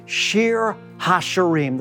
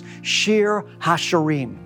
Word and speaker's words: Shir HaShirim: We're continuing our journey today in the Song of Songs, oftentimes called the Song of Solomon, pronounced in Hebrew, Shir HaShirim, the Song of Songs, Shir HaShirim.